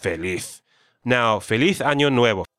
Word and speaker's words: nuevo: So the pronunciation tip I have for you all is that nuevo is a two-syllable word, right? Nue and vo.